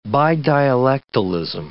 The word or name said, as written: bidialectalism